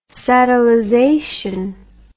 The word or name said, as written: satellization